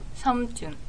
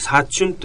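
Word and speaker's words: サムチョン (삼촌): じゃあそのお父さんはサムチョン。 – はい、サムチョンっていうのが正しい発音で、口語的になってチュンって言ったりしますけど、それは慣例的にそういうふうに言う。サチュンとも言うんだね。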